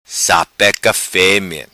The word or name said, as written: Sapeca Femea